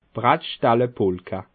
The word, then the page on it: Brattstelle Polka: Bas Rhin; Schiltigheim